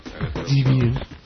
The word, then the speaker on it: Debian: Debian. – Yeah, Debian. Well, we can run Exxon. – Debian. Especially like Debian, which tends to patch a lot. – But things like Debian, like you said, Debian patches heavily.